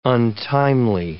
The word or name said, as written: untimely